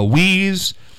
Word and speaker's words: Weeze: If you're just tuning in, the announcement is the Weeze, which rhymes with sleaze, by the way.